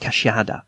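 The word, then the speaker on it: queixada: the quixia, pronounced cachiada, of the Brazilians, which would make our collection of the big mammals of the Brazilian forests almost complete.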